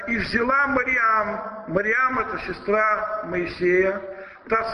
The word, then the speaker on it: Мариам: И в евангельских чтениях иногда имя Девы Марии прочитывается как Мариам на церковнославянском языке. Это более правильное произношение этого имени.